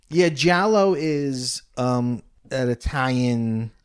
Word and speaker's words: giallo: is it pronounced Giallo? – Giallo.